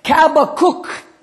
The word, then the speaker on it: Habakkuk: Habakkuk is the way the Hebrews pronounce it.